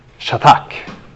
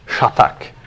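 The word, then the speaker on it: Shatak (חתך): Och det här ordet för bestämda är ett hebreiskt ord som uttalas så här. Shatak. Shatak.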